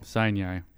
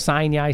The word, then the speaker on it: Sinai: S-I-N-A-I. – And how do you pronounce it? – Sinai. – Really? – Yeah, I guess so. That's got a weird emphasis on a weird syllable. I would say that would be Sinai. – Yeah, yeah, like out of the Bible, correct.